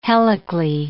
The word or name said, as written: helically